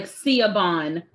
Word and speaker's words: Siobhan: It's Irish. That's why. Yeah. So it's like Siobhan, but it's p- pronounced Siobhan.